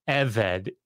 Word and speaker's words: eved: So the word is eved, E V E D. The emphasis is on the first syllable.